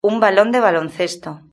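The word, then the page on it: un balon de baloncesto: Spain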